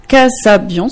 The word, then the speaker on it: Kasabian: Donc, pour cette fin d'interview, vous avez choisi une chanson, I Hear Voices de Kasabian, si je prononce bien. – Oui.